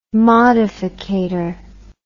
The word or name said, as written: modificator